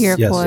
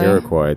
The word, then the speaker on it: Iroquois: Iroquois. – Yes, Iroquois.